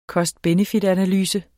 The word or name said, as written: cost-benefit-analyse